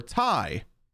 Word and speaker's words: Ti: And two, they are still arguing internally and conducting a civil war about whether it's pronounced T-I or Ti. It's T-I, by the way. It's the 3090 Ti.